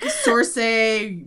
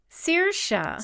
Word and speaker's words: Saoirse: Like Sorsay Ronan. – Saoirse.